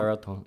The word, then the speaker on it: Raton: Raton.